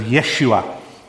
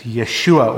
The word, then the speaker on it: Yeshua: Or I think the pronunciation in Hebrew is something along the lines of Yeshua, because the J becomes a Y, the way it's pronounced. So it's Yeshua or Joshua, which in the Greek becomes Jesus.